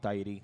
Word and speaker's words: Tyre: ¿Cómo se pronuncia? T-Y-R-E. – Tyrone. Vamos a llamarlo Tyrone porque se escucha mejor. – No, Tyree se llama.